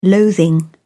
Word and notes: loathing: British English

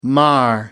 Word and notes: mar: American English

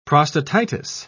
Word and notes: prostatitis: British English

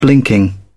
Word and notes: blinking: British English

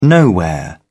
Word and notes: nowhere: British English

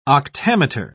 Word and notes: octameter: British English

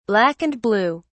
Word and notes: black and blue: British English

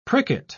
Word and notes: pricket: British English

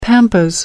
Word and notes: pampas: American English

